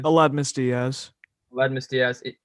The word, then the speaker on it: Aledmys Díaz: So, and they have Aledmys Díaz. Is that how you pronounce his name? – Aledmys Díaz.